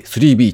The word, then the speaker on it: BBB: JR 東日本グループは新宿駅南改札内コンコースに、空間全体を統一的に演出可能なイマーシブなメディア空間、新宿 3B、新宿は漢字、アルファベットの BBB と書いて 3B と読むそうですね。